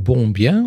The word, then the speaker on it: Bombien: Maar je hebt tussendoor ook nog ik weet nooit hoe ik het moet uitspreken. Hotel Bombien of Bombien. Ja, helemaal goed.